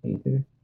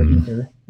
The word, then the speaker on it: aether: It depends on where you're at and how do you pronounce it. And magic, it's aether. – Yeah. And, or ether. Yeah, ether.